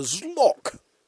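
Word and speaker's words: Xlokk: Well may you grovel, disbelievers, as I present the Zlok. That's X-L-O-K-K, pronounced exactly as it looks, with great difficulty.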